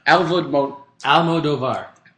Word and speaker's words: Almodóvar: The first one is Pedro Almodóvar and Dario Argento, which Argento beat out. – Almodóvar. – It's fucking A-L-M-O-D-O-V-A-R. – Oh, there you go. – Yeah, Almodóvar.